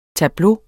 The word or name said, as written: tableau